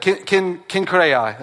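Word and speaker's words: Cenchreae: It's Kinkai. That's how you say it. Kinkai. That's it.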